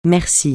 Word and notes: merci: francais

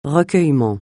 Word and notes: recueillement: francais

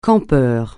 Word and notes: campeur: francais

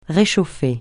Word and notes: réchauffer: francais

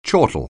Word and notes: chortle: anglais

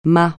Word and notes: MA: francais

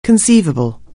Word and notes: conceivable: anglais

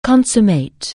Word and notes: consummate: anglais

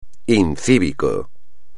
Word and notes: incívico: espagnol

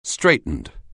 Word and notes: straitened: anglais

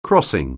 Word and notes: crossing: anglais

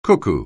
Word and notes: cuckoo: anglais

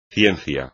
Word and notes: ciencia: espagnol

